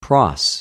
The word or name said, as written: προς